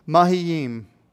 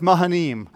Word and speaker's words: Mahanaim: This name, Mahanaim, I'm butchering it, but it's Mahanaim. That's as close as I'm going to get.